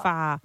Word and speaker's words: pho: And it's the same with the Vietnamese noodle soup, which it's P-H-O, so you're like, oh, can I please have a pho? – Yeah. – And then you get laughed at, and you're told, no, it's pronounced Far. – It is far.